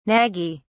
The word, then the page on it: naggy: British English